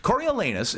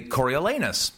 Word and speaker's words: Coriolanus: The best part of Coriolanus is getting to say Coriolanus, because the last two syllables are naughty.